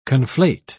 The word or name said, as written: conflate